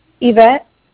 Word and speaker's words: Ivette: Yvette.